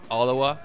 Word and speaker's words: Ottawa: Ottawa è la pronuncia giusta, vero? – Sì, abbastanza. – Abbastanza? Cosa vuol dire? – Nel senso che lì la chiamano Ottawa.